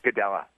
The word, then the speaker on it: Gadelha: Um, Claudia Gadelha. – Gadelha. – Gadelha, of course. She's out of Brazil? – Yeah, Claudia Gadelha out of Brazil.